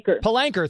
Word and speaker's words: Palanker: Palanker, thank you.